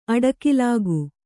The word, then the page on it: ಅಡಿಗಲ್ಲಿಕ್ಕು: aḍigallikku